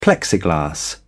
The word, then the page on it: plexiglas: British English